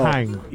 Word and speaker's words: Mannheim: De acordo com ele, a cidade de Mannheim, provavelmente não é assim que se pronuncia no meu sotaque alemão, peço perdão. – Não, não, tá ótimo teu alemão. – Mannheim. – Isso aí. É isso aí mesmo.